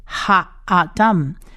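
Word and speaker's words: Ha-Adam (האדם): 的音，那它其实意思就是人类，人的意思， Ha-Adam Ha-Adam Ha-Adam 这个字呢有四个字母，第一个字母是希伯来文字母的 Hey， Hey 是发 H 的音加上下面的小 T 长母音 A，所以是 H-A-Ha Ha-Adam。 Adam 呢第二个字母呢是啊的音，因为它下面的小 t 是长母音啊，它要发音，而上面的这个 alef 我想听众朋友都已经很熟悉了，它就是末音字母不发音，所以 ha adam 的 ha a。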